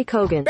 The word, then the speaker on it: Keoghan: And everybody on the episode's calling him Barry Keoghan. I called him Kogan. – Barry Keoghan. – Barry Keoghan! Sorry.